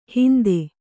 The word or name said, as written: ಹಿಂದೆ